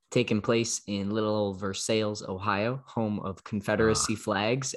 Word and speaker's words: Versailles: That's why it's Versailles, not Versailles. – Yep. Versailles, if we're being phonetically inclined to our American friends there.